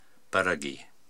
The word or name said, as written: Baragi